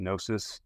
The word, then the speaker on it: Gnosiiis: And also another thing, it's pronounced Gnosiiis. The G is silent.